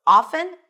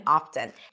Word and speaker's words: often: However, we have seen that the T started to come back. At some point, it started becoming a valid and acceptable pronunciation, and now you'll hear both, often and often. And sometimes the same speaker might say the two different pronunciations depending on the focus and emphasis they put on this word. Okay? So you can often pronounce it as often, and you can often pronounce it as often. It is up to you, my friend.